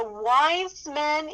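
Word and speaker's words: Weizmann: The Wise Men Institute, okay, I'm going to spell it. It's W-E-I-Z-M-A-N-N. It's not wise man like they've got any brains here, okay?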